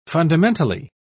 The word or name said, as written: fundamentally